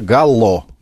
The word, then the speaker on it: галло: Ну, правильный ответ, этот, значит, феномен называется галло. – Да, на «о» ударение.